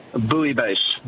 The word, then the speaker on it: bouillabaisse: And am I mispronouncing the word? Do you know? – Bouillabaisse. – Bouillabaisse, of course, because the L's are silent.